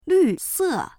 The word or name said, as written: lv4se4